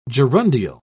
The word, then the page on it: gerundial: British English